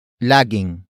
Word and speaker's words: laging: The first word is lagging. Lagging. Lagging. Lagging is spelled as L-A-G-I-N-G.